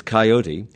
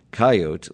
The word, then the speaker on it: coyote: So the preferred pronunciation is coyote, with the Americanized version coyote listed in most dictionaries as a secondary pronunciation.